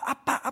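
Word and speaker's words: 아빠 (אבא): 아이들이 뭐라고 그러냐면 아빠 아빠 아빠 하면서 다닙니다. 우리 한국말하고 똑같아요. 정확하게 발음도 똑같아요.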